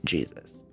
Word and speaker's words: Jesus: Army cavalry officer, and his mother was the Mexican-born Carmen Mercedes Moreno, so his middle name was probably pronounced Jesus, although once he entered the U.S. Civil Service, he pronounced it Jesus.